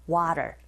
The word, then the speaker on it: water: 네, 모음과 모음 사이에 t 사운드가 있으니 flap 현상에 의해서 water 이렇게 발음하시면 됩니다.